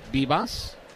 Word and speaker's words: Vivas: So Bibas, who spells his last name V-I-V-A-S, but he pronounces it Bibas, is now 2-for-2 in this game, and that's five hits for the Nationals.